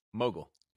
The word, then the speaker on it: Mogill: Mogill.